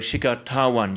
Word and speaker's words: Shikhar Dhawan: So Shikhar Dhawan, you make sure that you pronounce the H.